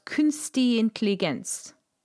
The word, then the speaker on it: künstliche Intelligenz: Which I looked up and I couldn't find a forward for Generate, but KI is pronounced there, it's pronounced künstliche Intelligenz.